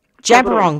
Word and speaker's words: Djab Wurrung: Jabiron, that's how you pronounce it.